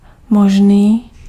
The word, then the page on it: possible: /ˈpɑs.ə.bl̩/; [ˈpɑs.ə.bl̩]; /ˈpɒs.ᵻ.bl̩/